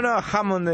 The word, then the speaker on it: Hammond: Hammond, ¿cómo se dice? – No, Hammond, está bien.